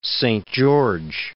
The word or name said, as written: Szent-Gyorgyi